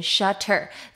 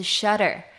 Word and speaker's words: shutter: 那其实念 T 这个字啊如果放在中间的时候你们可能会注意到母语人士呢并不会把 T 的音发得很明显念成 shutter 这种感觉。他们会有一个舌头带过去的感觉是 shutter。 shutter。